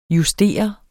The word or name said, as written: justere